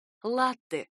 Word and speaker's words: латте: Ударение в этом слове ставится на первый слог. Оно пришло к нам из итальянского, в котором произносится именно так — «латте», и переводится как «молоко».